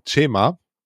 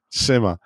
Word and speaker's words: Chema: Und tatsächlich die korrekte Aussprache von ist Chema. Das ist nämlich die Kurzform des Namens José. Und der erste Teil des Namens wird wie das deutsche Wort Che ausgesprochen, wobei das Tsch wie in Tschüss klingt. Aha. Also Chema. Und der zweite Teil wird das deutsche Wort Ma ausgesprochen wie in Mama.